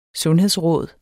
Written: sundhedsråd

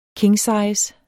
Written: kingsize

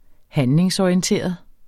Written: handlingsorienteret

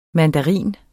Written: MANDARINEN

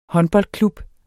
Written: håndboldklub

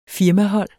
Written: firmahold

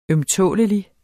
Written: ømtålelig

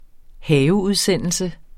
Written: haveudsendelse

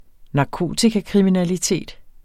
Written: narkotikakriminalitet

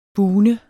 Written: BUGNEDE

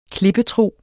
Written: klippetro

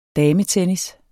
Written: dametennis

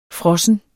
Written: frossen

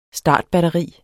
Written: STARTBATTERI

